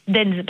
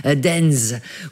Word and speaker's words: Deinze: Et il y en a un, je m'excuse pour la prononciation, c'est Dinze. Ça s'écrit D-E-I-N-Z-E. Denz, pardon. – C'est en Flandre, ça, ou c'est peut-être une commune à facilité. Denz, oui, c'est ça.